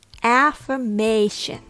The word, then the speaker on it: affirmation: Please note that the noun affirmation and the verb affirm has a different rhythm, or the stress is on a different syllable. The word affirm has the stress in firm. So the a becomes a schwa sound.